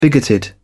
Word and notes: bigoted: British English

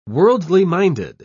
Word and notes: worldly-minded: British English

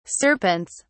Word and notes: serpents: British English

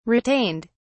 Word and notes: retained: British English

